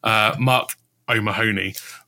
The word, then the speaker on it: O'Mahony: And then also Marco Mahoney, although I've definitely put the emphasis on the wrong play there. I made him sound Spanish. Mark O'Mahony from Brighton.